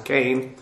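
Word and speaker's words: Cain (כֵּן): Verse 3 begins with a construction that is comprised of the preposition ale, al, upon or on, and the adverb kin. Cain, actually, it's a sere. It should be pronounced Cain.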